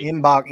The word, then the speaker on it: Mgbako: Mgbako. Yeah, you're right. Mgbako. Mackenzie, yeah. Mgbako out of Duke is a guy that Nolan Smith has been in on for a long time now.